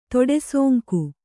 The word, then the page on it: ತೊಡೆಸೋಂಕು: toḍe sōŋku